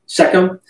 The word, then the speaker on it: Shechem: Shechem, I think I said that right. – Shechem.